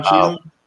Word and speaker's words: Cheadle: You know, I think you said it right, Don Cheadle.